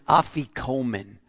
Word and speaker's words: afikomen: It's a Greek word pronounced afikomen. A-F as in Frank, I-K-O-M-E-N.